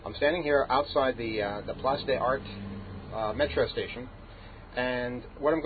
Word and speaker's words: Place des Arts: So right now I'm standing outside a station known as, I hope I pronounced this right, Place des Arts. And I know it's not Place des Arts, but I think that's how you pronounce it.